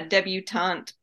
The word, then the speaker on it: debutante: And then the second one is called Husk, and it's about a how do you say that word? – A debutante? – Yeah, debutante ball, where this girl is, you know, being trained to enter it.